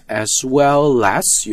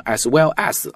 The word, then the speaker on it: as well as: 那么在读的时候要注意一下 as well as 有一个连读，不要读成 as well as 啊，我经常听中国同学会读成 as well as, no, as well as, as well as, less. L 和 A 是可以连读的 as well as, 同样的道理 biological experiment, 不要读成 biological experiment, biological experiment.